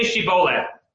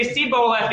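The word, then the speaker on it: shibboleth: And if he said no, they would say to him, then say Shiboleth. But he would say Siboleth because that's the way that Ephraimites pronounced words. They couldn't say the SH sound.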